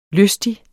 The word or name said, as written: lystig